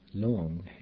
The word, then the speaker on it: lung: Lung, air or wind, for instance, is not pronounced like the human organ, but with an U sound, as in the word scuba.